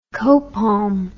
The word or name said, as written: copalm